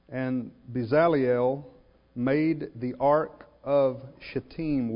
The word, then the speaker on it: Bezaleel: What I want you to notice is Bezaleel, and that is the way you pronounce it, just for the record, I double-checked, Bezaleel made the Ark of the Covenant.